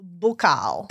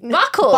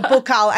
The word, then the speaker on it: buccal: So, it's pronounced bucal. Because I always heard buckle. Buckle, yes. No, bucal.